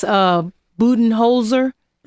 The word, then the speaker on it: Budenholzer: Milwaukee Bucks, Budenholzer.